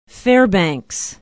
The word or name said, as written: Fairbanks